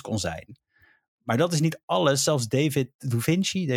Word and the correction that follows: Duchovny: ik kan zijn naam niet uitspreken, maar hij speelde... – Duchovny. – Hij speelde Fox Mulder in de X-Files, om even zo te zeggen. – David Duchovny.